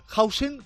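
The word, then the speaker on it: Huijsen: Ya saben que España empataba 2 en Países Bajos, en Rotterdam, con el debut de un central, Hausen, que es como se pronuncia, a pesar de que es malagueño de adopción, pero le pitaban en Holanda por haber escogido jugar con España.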